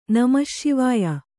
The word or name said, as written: ನಮಶ್ಶಿವಾಯ